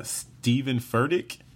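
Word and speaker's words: Steven Furtick: Anyway, so the good vibe, this week's good vibe comes from Stephen Furtick.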